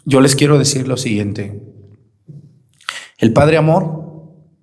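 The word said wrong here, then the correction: Amorth: Amort, se escribe Amort con T al final, fue un exorcista del Vaticano.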